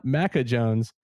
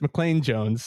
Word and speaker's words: Maclean Jones: And of course, we're going to tell Kit McConico too that it's not Macca Jones. It's McLean Jones.